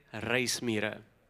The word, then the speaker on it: reismira: We call them reismira. Everyone say reismira. Got to roll your R's. Reismira.